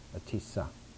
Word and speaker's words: Atisha: Now the great Indian sage who brought this into refinement was named Atisha, or sometimes the proper pronunciation in Tibetan would be Atisa. But Atisha, I'm used to using that pronunciation, so I'll continue.